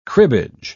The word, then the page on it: cribbage: British English